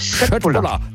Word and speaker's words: Köttbullar: Ja, Köttbullar. – Ja! Oder wenn man es ganz korrekt aussprechen, ich habe mir sagen lassen, das K wird in Schweden wie ein Sch ausgesprochen. Also wenn es Schöttbullar, hat es doch geklappt.